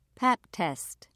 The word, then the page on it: pap test: British English